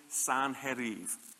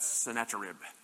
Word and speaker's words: Sanherib: Well, the city was the ancient capital of the Assyrian Empire, and it was established by King Sanherib. I always want to pronounce that Sennacherib, but that's what you do with your barbecue leftovers.